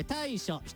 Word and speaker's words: 大暑: 次大きく暑いと書いて大暑、 7 月 23 日頃、外気温、冷房代も急上昇。